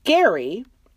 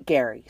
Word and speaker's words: Gerry: It's spelled G-E-R-R-Y, which in my mind is Jerry, but they all pronounce it Gary. So we'll go with it. We'll just say Gary.